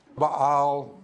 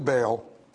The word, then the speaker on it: Baal: Now, you could pronounce this a few ways. Baal, Baal. We'll do Baal because we're in the south.